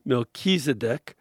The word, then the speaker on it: Melchizedek: When I watch the Daily Mass on TV, I hear the name of the priest Melchizedek pronounced with the accent on the second syllable. Knowing that you've studied Middle East languages, would you agree that this pronunciation is correct? Not if you're saying the name in Hebrew, it's not correct. But in English, it is."